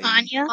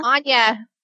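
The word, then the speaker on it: Anyia: Naked Quidditch Match by Anya. – Anya.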